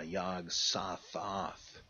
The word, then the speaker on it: Yog-Sothoth: Yog-Sothoth, though I have heard that. I tend to think that the two O's are meant to rhyme, Yog-Sothoth.